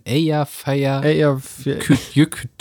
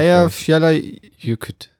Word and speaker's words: Eyjafjallajökull: Nein, Eyjafjallajökull. – Spricht er sich. – Eyjafjallajökull. – Irgendwie wird das T da weg. Ich habe mich zumindest versucht, etwas mit der Aussprache zu beschäftigen. – Das T ist stumm.